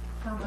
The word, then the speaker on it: Camara: when I put this Dom Helder Camara, is that how you would say it? Camara? – How do you spell it? – C-A-M-A-R-A? – Camara. – Mm-hmm. Camara. Thank you.